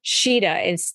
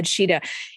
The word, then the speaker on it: Tschida: His name is Brad Shida. It's spelled really weird. T-S-C-H-I-D-A, Brad Shida. He is something.